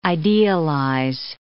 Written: idealize

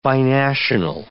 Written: binational

